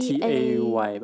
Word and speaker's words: Tay: 比方说，我们还没有到就是自动车要不要撞人这种等级，就是在那么几年前，微软做过一个还蛮著名的实验，他写了一只机器人的 AI 叫做 Tay，嗯，T T A Y 吧，T A Y，对，应该发音叫 Tay。然后她是一个设定上是十九岁的少女啊。